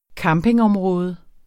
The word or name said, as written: campingområde